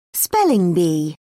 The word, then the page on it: spelling bee: British English